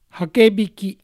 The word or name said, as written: Hakebiki